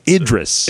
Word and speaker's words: Idris: Idris Elba, and we've learned how to pronounce his name since last week. – Idris, yeah, somebody tweeted at us too that. – Idris. – Idris. – Yes. – Not Idris. Don't say it like that. – That would be wrong. – That's wrong. Don't.